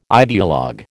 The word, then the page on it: ideologue: American English